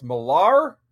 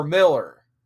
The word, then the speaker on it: Millar: Is it pronounced Millar or Miller? And he was just kind of like, whatever you want.